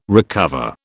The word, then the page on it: recover: British English